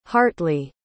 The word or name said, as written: hartley